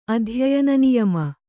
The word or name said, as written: ಅಧ್ಯಯನನಿಯಮ